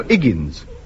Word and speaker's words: Higgins: No, Higgins. No, the H is silent as in oaths.